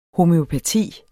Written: homøopati

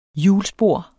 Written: HJULSPOR

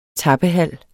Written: tappehal

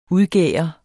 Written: udgære

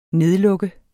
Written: nedlukke

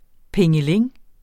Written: plingeling

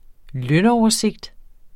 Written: lønoversigt

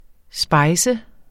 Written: spice

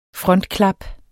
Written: frontklap

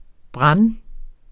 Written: brande

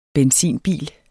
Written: benzinbil

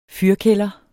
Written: fyrkælder

